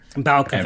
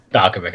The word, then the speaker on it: Balkovic: Is it Rachel Blevick? – Balkovic. – And mind you, Balkovic.